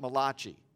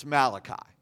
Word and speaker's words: Malachi: You don't pronounce it Malachi, it's Malachi.